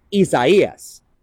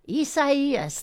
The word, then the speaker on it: Isaias: Isaias. – Isaias.